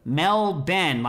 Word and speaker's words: Melbourne: I'm from Melbourne, Australia, which is pronounced Melbourne, not Melbourne. What do you think about that, Stas? Melbourne. – I have chills. – Wait, did he put that in there, or you were just telling people? – No, I was told to say this. It's pronounced Melbourne. – Yeah. Okay. – Melbourne, like my brother Ben.